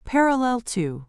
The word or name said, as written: parallel to